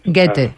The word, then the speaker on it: Goethe: Escolta'm, i en eixe Faust, que de Goethe, com es pronuncia este Goethe? – Sí, Goethe seria correcte.